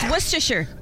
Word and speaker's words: Worcestershire: Oh no, it's actually not. It's Worcestershire. That's how you say it.